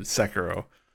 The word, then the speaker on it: Sekiro: Sekiro. – The question. – I said it right the first time, I think. I'm doubting myself. – You did. Sekiro.